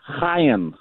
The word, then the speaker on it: Chaim: So I wasn't going to bring this up at all, but since you mentioned it during trending, both of you are saying his name wrong. – Yeah, I thought it was Chaim. – It is Chaim.